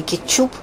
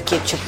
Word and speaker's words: кетчуп: «Дождь» и «По средам» – все это, в общем, «Фольга» и «Кетчуп» – все это ударения, которые ушли, и, в общем, нечего об этом говорить, потому что язык меняется. И если современные грамотные носители языка, которых мы все готовы признать грамотными, говорят всегда «Фольга», «Кетчуп», «Йогурт» и там что еще, «Дождь» и «По средам», то, в общем, надо признать, что тут норма уже изменилась.